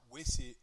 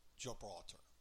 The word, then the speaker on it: Gibraltar: And she goes, Gilbraltar, which is Gibraltar.